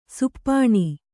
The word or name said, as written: ಶಪನ